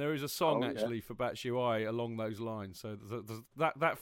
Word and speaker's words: Batshuayi: Is that the official pronunciation? – Yes, Batshuayi? I believe so. Batshuayi. – Batshuayi. All right. – Batshuayi. – Thank you very much. – So I'm told. Well, it rhymes with that.